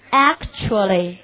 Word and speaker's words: actually: Then the last one，好，最后一个看怎么读，actually，重音在第一节，actually，OK，actually、okay, actually means 事实上，事实上我们一个短语叫 in fact，我们大家更多用的叫 in fact，actually。